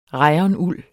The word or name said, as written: rayonuld